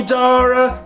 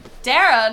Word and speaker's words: Dara: It's the Emmy, Dara, and Bob podcast. Two fashion bitches and a cranky old man. – What's your name? – Dara, not Dara. – Oh, Dara. Rhymes with Farrah, like Farrah Fawcett Majors.